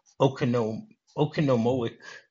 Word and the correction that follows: Oconomowoc: Wisconsin. – Oconomowoc. That's how you say it.